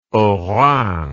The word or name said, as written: haut rhin